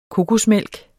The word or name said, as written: kokosmælk